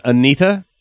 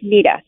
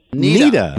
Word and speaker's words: Neta: Did you say her name is Anita? – It is just Nita, N-E-T-A. – Nita.